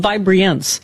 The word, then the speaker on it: Vibreance: It's, I added the extra syllable on purpose. It gives me vibrance, but it is called Vibreance.